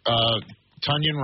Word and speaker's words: Tonyan: They got Robert Tonyan, rhymes with Funyan, to go along with the wagon, Darnell Washington, and of course, Freiermuth.